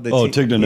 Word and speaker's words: Tignanello: Yeah, no, the Tignanello. – Tignanello, yeah.